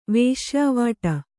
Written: ವೇಶ್ಯಾವಾಟಿ

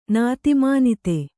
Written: ನಾತಿಮಾನಿತೆ

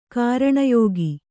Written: ಕಾರಣಯೋಗಿ

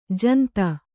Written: ಜಂತಿ

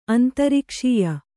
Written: ಅಂತರಿಕ್ಷೀಯ

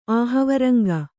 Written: ಆಹವರಂಗ

